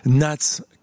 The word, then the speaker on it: Knats: Nats, K-N-A-T-S, on prononce pas le K, comme souvent en anglais.